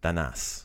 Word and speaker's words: Danas: So Danas, obviously, like we do all of our D's, we do a soft D sound. We put that tongue at the back of our teeth to pronounce it correctly. Danas.